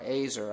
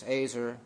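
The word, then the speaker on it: ezer: This word helper in the Hebrew, it's E Z E R, Azer, I think is how it's said.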